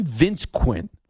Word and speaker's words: Vince Quinn: See, I never dealt with that because I'm Vince Quinn.